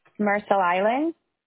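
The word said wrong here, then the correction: Mercer Island: Marcel Island? – Mercer, M-E-R-C-E-R.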